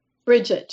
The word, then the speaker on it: Brigitte: Brigitte, just plain Brigitte. Okay, more an American pronunciation.